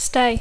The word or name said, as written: stay